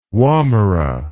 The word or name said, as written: womera